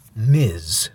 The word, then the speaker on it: Ms.: But Ms. is pronounced Ms. So this is Ms. Tree. If they meant it to be pronounced Miss Tree, to further go on the play of mystery, that's not quite right. That's not how you pronounce that. That's Ms., not miss.